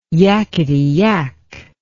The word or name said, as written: yackety yak